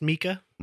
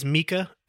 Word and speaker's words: Mika: And it is pronounced Mika. Mm-hmm. I know that a lot of people say Mika. It is Mika, and it took me forever to find an actual pronunciation.